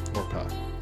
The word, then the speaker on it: Orpah: All right, Oprah's real name is Orpha. Orpa. Orpa. Orpa. – O R P A H.